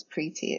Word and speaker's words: Preti: And then as a writer going into public events, it's funny because I don't particularly think I have an unpronounceable name. I mean, it's the same two syllables, Preti. It's very simple. But there's always this tendency for people to mispronounce it.